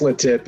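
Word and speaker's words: LeTip: Is that, am I pronouncing that right, or is it La Tip? – No, it's just La Tip.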